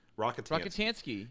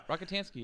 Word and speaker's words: Rockatansky: Have I been pronouncing that name wrong all this time? Max Rockatansky. – Rockatansky. – Yeah. – Rockatansky, yeah.